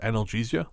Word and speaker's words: analgesia: According to an article I read, that what he experienced is called dissociative analgesia. I think I'm pronouncing that right. – Yeah, yeah.